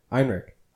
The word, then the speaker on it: Ehrenreich: We pronounced that right. Alden Ehrenreich. Ehrenreich. – Ehrenreich. – Yeah, it's like okay. Heinrich, but like Ehrenreich.